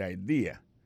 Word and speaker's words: idea: T-H-A-T, apostrophe S, good, G-O-O-D pour good, ça veut dire bon, bonne, idea, c'est I-D-E-A, ça veut dire idée, that's a good idea. Alors, accent vient tomber dans la deuxième syllabe là, one idea. Alors, moi, c'est idée, vous faites trois syllabes vraiment, I-D-A.